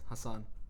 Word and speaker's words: Hassan: It's Hassan. – Hassan? – Yeah. – They didn't even pronounce that right. Naz, period. – Naz, Haas. – Yeah, Haas, Naz, something like that.